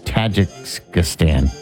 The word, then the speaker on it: Tajikistan: Nepal, China, India, Pakistan, and then Kazakhstan. Is that really how you say it? Tajikistan? No, Tajikistan.